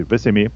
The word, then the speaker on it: Vesemir: qui est Yesemir. Yasemir, je pense que ça, se prononce. Ben, non, Vesemir, excusez, Vesemir